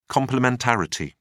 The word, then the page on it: complementarity: British English